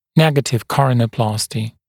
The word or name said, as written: negative coronaplasty